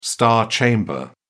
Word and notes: star chamber: British English

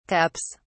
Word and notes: cups: British English

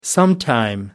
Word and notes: sometime: American English

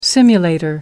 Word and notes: simulator: American English

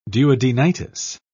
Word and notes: duodenitis: British English

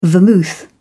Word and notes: vermouth: British English

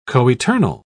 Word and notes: coeternal: British English

